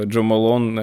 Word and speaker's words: Jo Malone: Ну, в общем, у нас сегодня герои Кира, это пиар-директор, пиар-менеджер брендов, э, Джо Малон, э, Фредерик Маль или Лабо.